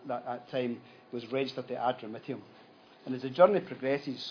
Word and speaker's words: Adramyttium: Adramyttium. I got the same pronunciation as Hamish, so we're either both correct. Hopefully, I'm sure we are. Adramyttium, and it's a bit like the Calmac ferry that I was on going to Brodick.